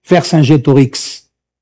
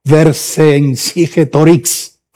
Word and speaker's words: Vercingétorix: Y se llama, muy difícil de pronunciar, en francés, Vercingétorix. Vercingétorix.